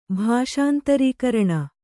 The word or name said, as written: ಭಾಷಾಂತರೀಕರಣ